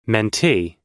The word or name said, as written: mentee